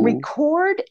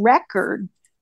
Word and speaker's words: record: Things like wind and wine and lead and lead, L-E-A-D and L-E-A-D. So mm-hmm. record and record would be examples of homographs. They, of course, they're spelled the same. They're right. – pronounced differently. And they mean different things. They have the accent on different syllables. Record is on the first syllable. Record accent is on the second syllable.